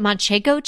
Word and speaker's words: Manchego: And then it had, is it Manchego cheese? – Am I saying that right? Yes, you are.